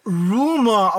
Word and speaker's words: RMR: Der wird Rumor ausgesprochen. – Ja, genau. – Jobsy hat es mir auch gesagt, dass er eigentlich Rumor heißt. Ich habe es aber wieder verdrängt und immer nur RMR.